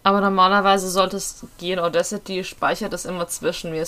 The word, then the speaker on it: Audacity: Ich habe es als Audacity kennengelernt. – Okay. – Es gibt ja auch das englische Wort Audacity.